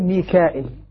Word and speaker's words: ميكائيل: من الباقين الذين يهمزون يقول ميكائيل. إذن الباقين ميكائيل. كما نطق بها.